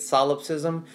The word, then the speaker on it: solipsism: Yeah, I believe that's the right way to say it. Everybody, once again, in the comments, you can fix us up, show us how to say it the right way, but that's how we think we say it, solipsism.